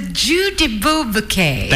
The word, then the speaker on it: Jeu de Bilboquet: Jeu de Bilboquet? – Yeah. The Jeu de Bilboquet. – The Jeu de Bilboquet. – That is correct. That's how you say it.